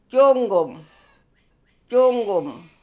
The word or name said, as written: Joonggoom